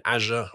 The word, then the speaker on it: Azure: I went on a course recently learning about some new Microsoft thing in Azure or Azure, as we pronounce it in England, and doing all this stuff.